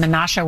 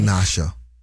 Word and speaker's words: Menasha: More than 1,000 homes and other buildings in Menasha were damaged or destroyed. – Menasha. It's not Menasha. Menasha. Menasha. It's a little Wisconsin thing.